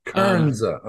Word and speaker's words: Kernza: How do you pronounce it? – Kernza. – Kernza. Okay. – So it's like a combination of kernel and then Kanza, which is the indigenous name for this part of the country.